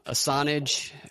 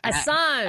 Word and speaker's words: Assange: I didn't see what the Julian, whatever's, how do you say his last name? Assange. – Assange.